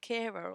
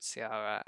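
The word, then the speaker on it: Ciara: I think it's called Kira or Kiara. – Well, all I know is one R&B goddess called Sierra, and that's how it shall be pronounced from there on.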